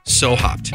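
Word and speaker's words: Sew Hopd: And they even spell their name in a cool way, like it's So Hopped, the beer, right? Well, they spell it SEWHOPD. So why spell it like I'm getting ready to sew up a seam?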